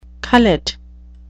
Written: cullet

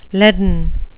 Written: leaden

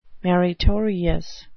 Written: maritorious

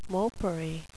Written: mopery

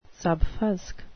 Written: subfusc

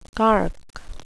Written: cark

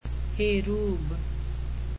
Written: hey rube

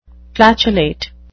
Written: flatulate

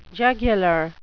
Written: jugular

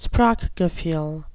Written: sprachgefuhl

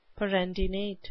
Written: perendinate

